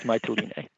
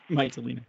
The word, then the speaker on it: Mytilene: Mytilene? How did you say it? I know you said it properly. – I have to admit, I don't actually speak Greek, so I might not be pronouncing it properly, but I believe it's Mytilene.